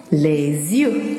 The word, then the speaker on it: les yeux: Les yeux，les yeux，这个单词发音有点难，小朋友们注意，轻轻的咬住你的舌头。 Les yeux，耳朵，l'oreille，l'oreille。